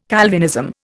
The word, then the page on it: Calvinism: Indian